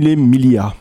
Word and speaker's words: Mille Miglia: Je suis pas sûre de bien le prononcer en italien. – C'est Mille Miglia.